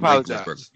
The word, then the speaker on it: Roethlisberger: And the only other guy that I could think that's close to it that would not be black is Ben Roethlisberger, but he's a dick. But Ben doesn't get the calls either. You mispronounced his name, sir. – That's Ben Roethlisberger.